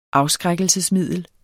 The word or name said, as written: afskrækkelsesmiddel